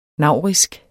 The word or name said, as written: naurisk